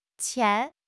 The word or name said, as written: qian2